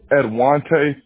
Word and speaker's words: Edwonta: Oh, Edwante Forrest.